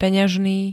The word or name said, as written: peňažný